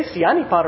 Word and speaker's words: Sianipar: The Batak text was read by Mika and Sari Sianipar. I can say Sianipar, right? Right? Because I heard them pronounce that.